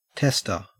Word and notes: tester: Australian English

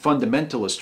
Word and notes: fundamentalist: American English

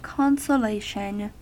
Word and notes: consolation: American English

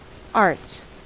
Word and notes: arts: American English

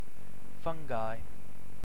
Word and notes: fungi: British English

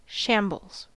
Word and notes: shambles: American English

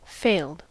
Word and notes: failed: American English

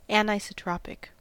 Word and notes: anisotropic: American English

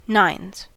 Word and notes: nines: American English

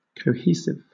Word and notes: cohesive: British English

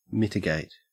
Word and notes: mitigate: Australian English

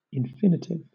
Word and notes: infinitive: British English

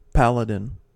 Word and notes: paladin: American English

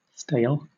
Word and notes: stale: British English

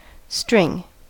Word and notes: string: American English

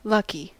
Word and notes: lucky: American English

